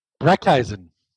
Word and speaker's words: Brecheisen: Brecheisen.